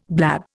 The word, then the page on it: blab: Indian